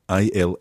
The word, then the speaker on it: ils: So we've got il in the plural, I L S, il. – Il. – But the S is silent unless it's followed by a vowel.